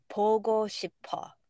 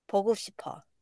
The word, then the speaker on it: 보고 싶어: Uh, 보고 싶어. 보고 싶어. And RM saying, 보고 싶어. – yeah, 보고 싶어. and then, you know, on and on. But yeah, uh, 보고 싶어.